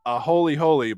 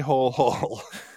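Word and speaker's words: āholehole: A holy holy, but it looks like a hole hole.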